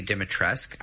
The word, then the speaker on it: Dimitrescu: Is- well, I I think the name is pronounced Lady Dimitrescu. I don't know why there's a U at the end.